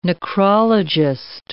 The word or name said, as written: necrologist